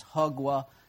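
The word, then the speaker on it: hugwa (火锅): It's hugwa. Yeah? Hugwa? Yeah? My Chinese mother-in-law, making sure I pronounce it correctly.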